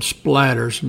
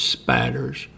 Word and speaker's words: spatters: As you can see here, it's spelled splatters, and that's not correct. It's the correct pronunciation and spelling is spatters.